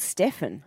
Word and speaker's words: Stephen: He liked to be called Stefan. – Yeah, Stefan. – Stefan, yeah. – Makes sense, Belafonte. – But it is spelled Stephen.